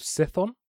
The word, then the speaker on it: Chthon: But very quickly, the god that I was talking about is called Cithon, or it's spelled Chthon, and it's the god of chaos.